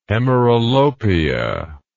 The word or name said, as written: hemeralopia